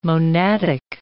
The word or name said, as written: monadic